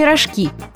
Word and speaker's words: рожки: Правильно, рожки, с ударением на последний слог. Все дело в том, что рожки с ударением на «о» – это то, что у животных на голове. А как раз-таки рожки – это те самые макароны в форме рожек. Что тоже важно, ударение в слове «рожки» никогда не падает на первый слог.